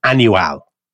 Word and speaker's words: Annual: General Fernandez Silvestre, after a deal of sort of marching about, he ends up in a fort in a place called Annual. So it's literally spelled like the word annual.